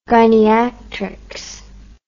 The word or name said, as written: gyniatrics